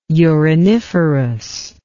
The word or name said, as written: uriniferous